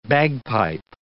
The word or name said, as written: bagpipe